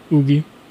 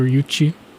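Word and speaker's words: Yuqi: G-Idle, my favorite member is probably Ugi or Yuchi, depending on how you want to pronounce it. She is Chinese, but the way they pronounce it in Korean is Ugi.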